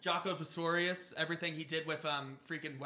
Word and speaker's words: Pastorius: It is Pastorius.